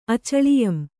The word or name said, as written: ಅಚಳಿಯಂ